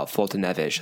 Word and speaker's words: Foltynewicz: Is it right? Or is it Foltynewicz? – No, I think it's Foltynewicz. I think that's right.